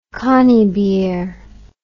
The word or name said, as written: conybeare